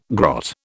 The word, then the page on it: grot: British English